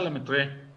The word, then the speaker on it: Lemaître: Le matiré. Le maitré. – Según yo se pronuncia Lemaître.